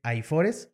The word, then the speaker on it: AIFORES: que es AIFORES, ¿lo dije bien? – Sí, sí, es correcto.